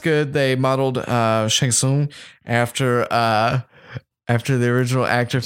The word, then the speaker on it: Shang Tsung: You're going to play as Shang Tsung or Spawn. Or very good pronunciation.